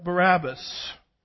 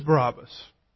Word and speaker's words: Barabbas: Now, who is Barabbas? Barabbas or Barabbas, it depends on which syllable gets the emphasis, as I like to say, was a robber.